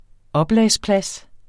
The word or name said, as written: oplagsplads